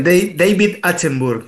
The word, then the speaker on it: Attenborough: No, está bastante bien. David Attenborough, viste que el Attenborough. – acento inglés, es.